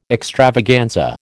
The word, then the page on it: extravaganza: American English